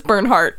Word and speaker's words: Bernhardt: It's like heartburn, but it's Bernhard. – How do you spell it? – B-E-R-N-H-A-R-D-T. – R-D-T.